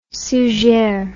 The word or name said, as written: suger